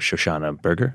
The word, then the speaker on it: Shoshana Berger: I'm curious why, how you and your co-author, Shoshana Berger, am I pronouncing that correctly? – Yep.